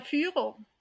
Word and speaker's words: Führer: Which I, you know, now I said it with like this American accent, which is so weird. – What's the, how would you pronounce it correctly? – It's der Führer. – Führer. – Führer. – Führer. I'm sorry, I'm speaking your language. Führer. – Yeah, it's you know, it's the U with the two little dots, the umlaut.